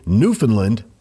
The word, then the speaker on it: Newfoundland: Generally, Newfoundland, the emphasis is on the new.